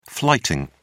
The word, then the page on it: flighting: British English